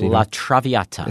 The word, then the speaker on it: La Traviata: La Traviata.